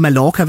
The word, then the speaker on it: Mallorca: Direkte fra Mallorca, eller skal jeg sige Mallorca eller Mallorca? Hvad foretrækker du? – Eller på spansk udtales som J, så det hedder Mallorca, hvor vi er.